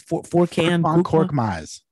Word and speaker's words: Korkmaz: Are they really going to play, how do you say his name? Korkmaz? – Korkmaz.